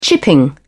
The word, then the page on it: chipping: British English